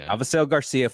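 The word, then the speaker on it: Avisaíl García: Avisail Garcia, 4 for 53.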